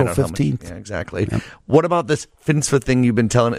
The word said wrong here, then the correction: FinCEN: Is it FINSA? Am I saying that right? – Yeah, FinCEN, which FinCEN.